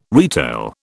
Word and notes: retail: British English

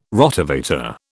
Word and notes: Rotavator: British English

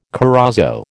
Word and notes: corozo: American English

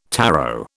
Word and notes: tarot: British English

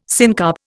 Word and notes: syncarp: Indian

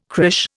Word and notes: crÊche: Indian

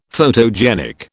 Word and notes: photogenic: British English

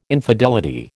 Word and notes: infidelity: American English

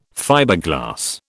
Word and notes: Fiberglass: British English

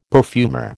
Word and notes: perfumer: American English

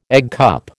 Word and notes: eggcup: American English

